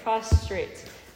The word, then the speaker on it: prostrate: I keep saying it wrong. – We did talk about prostate last night, though. – Oh, gosh. Yeah. Okay, prostrate. Okay, so it's meant for surrender.